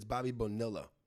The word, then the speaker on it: Bonilla: Two L's is a Y. – He shut little Danny off and was like, it's Bobby Bonilla. You said that wrong. And little Danny was like, huh? – It's not. I was like, Steven, it's Bobby Bonilla. He's like, oh, no, he's Italian or Spanish or something like that.